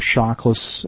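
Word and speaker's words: Shauklas: My name is Tom Shockless.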